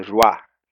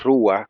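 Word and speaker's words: ruaj (רוח): Pero no me le pongan acento en la a la palabra ruaj, como algunos hermanos hacen, que dicen ruaj, sino es ruaj con acento en la U.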